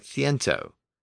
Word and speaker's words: ciento: Except in Spanish, the K sound became an S sound before the E and the I. So the Latin word centum evolved within Spanish and became C-I-E-N-T-O, which is pronounced as ciento, with the S sound.